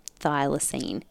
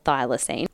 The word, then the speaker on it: thylacine: But these are just like the facts, right, about the thylacine. Is that how you say it? – I think so. I've always said it that way.